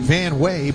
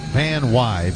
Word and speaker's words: Van Wey: It looks like Van Wey, but it's pronounced Van Wy, V-A-N-W-E-Y.